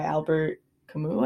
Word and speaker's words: Albert Camus: I just finished The Stranger by Albert Camus, I think. That's how you pronounce his last name.